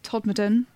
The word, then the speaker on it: Todmorden: 24th is Leeds, then Leicester, Hull, Todmorden, which I believe is pronounced Todmorden if you're from there, but we say it wrong.